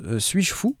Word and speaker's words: S8JFOU: Alors, ça s'écrit S8JFOU, mais ça se prononce Suis-je fou Et c'est intitulé L'autosuffisance par le software.